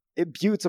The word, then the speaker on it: Butte: You mean Butte, Montana? – Butte, actually. That's how it's pronounced.